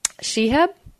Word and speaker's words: Shehab: But his name is Edward Shehab.